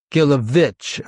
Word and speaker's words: Gilowitz: Gilowitz, like a V yes, sound. – But most people say Gilowitz or Gilowitz, which is fine. Kind of a witch at the end, basically.